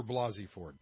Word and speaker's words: Blasey Ford: I believe Dr. Blasey Ford because she's telling the truth, and you know it by her story. – Oh, I was calling her Blakey Ford. I'm sorry. Dr. Blasey Ford.